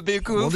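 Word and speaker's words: Baeckeoffe: Le Bekoff, j'ai tout bon, c'est comme ça qu'on dit ? – C'est pas mal du tout, le Bekoff.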